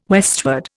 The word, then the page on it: westward: Indian